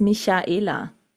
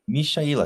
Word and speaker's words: Michaela: No, the correct pronunciation is Michaela. It's not with the K. Michaela, because I have a niece.